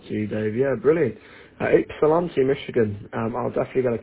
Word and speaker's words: Ypsilanti: Yes, the YP is kind of silent. Everybody pronounces it IP, IP, like an IP address, Ypsilanti. It's got a Y and a P, but we call it Ypsilanti.